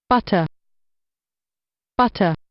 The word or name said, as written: butter